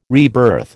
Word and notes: rebirth: American English

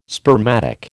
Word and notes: spermatic: American English

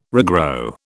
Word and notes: regrow: British English

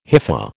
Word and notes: hypha: American English

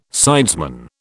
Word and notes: sidesman: British English

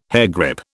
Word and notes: hairgrip: British English

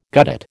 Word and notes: guttate: American English

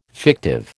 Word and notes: fictive: American English

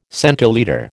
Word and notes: centilitre: American English

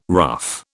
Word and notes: rough: British English

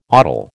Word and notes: odal: American English

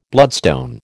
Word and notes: bloodstone: American English

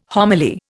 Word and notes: homily: Indian

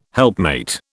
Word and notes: helpmate: British English

